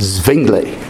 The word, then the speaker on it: Zwingli: The W is pronounced as a V in that part of the world. Zwingli.